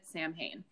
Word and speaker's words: Samhain: and it is spelled S-A-M-H-A-I-N. So if you've seen that, a lot of people pronounce it Samhain because they've never heard, it's like an Irish Gaelic word, Samhain.